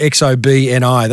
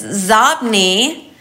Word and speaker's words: Xobni: This one is spelled X-O-B-N-I. Any idea how to pronounce that or what it means? – I'd probably pronounce it X-O-B-N-I. That's how I'd pronounce it. – Yeah, it's Zobni, and it's inbox spelled backwards.